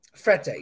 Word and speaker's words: Frette: Is that that's how you say it? – Frette, yeah.